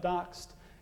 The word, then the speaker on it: D0X3D: So this is the website, D0X3D, pronounced Doxed. Let me show you, so it's all available on GitHub.